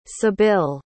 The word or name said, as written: sebille